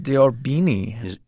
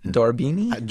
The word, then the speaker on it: d'Orbigny: Yeah, Alcide d'Orbigny. – Is that how you say it? – Orbigny? D'Orbigny? – D'Orbigny, maybe.